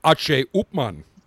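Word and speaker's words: H. Upmann: Listen, I mean, most people say H. Upman, but I know in Spanish it's H. Upman.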